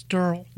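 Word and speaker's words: Darrell: I said that Daryl said that once he retires, he will never turn left again. – Yeah. – Ah. – You got to say his name right. It's Durrell.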